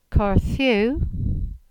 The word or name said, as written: Kardhu